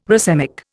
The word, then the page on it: racemic: Indian